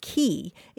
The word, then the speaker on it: quay: And so, David, yeah, the word spelled QUAY is usually pronounced key. It came into English in the 1300s, spelled K-E-Y, but then it was changed to the French spelling, which was Q-U-A-Y.